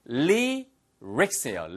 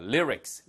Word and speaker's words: lyrics: 요 발음 좀 어렵습니다. Lyrics예요. Lyrics. Lyrics. 복성틀만 쓰이고요.